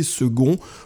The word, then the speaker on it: second: Notons qu'en anglais, second s'écrit comme en français, mais que l'on prononce bien le C. En espagnol, en revanche, segundo s'écrit et se prononce avec un G.